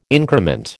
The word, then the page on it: increment: American English